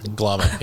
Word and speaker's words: Glamann: Glamann.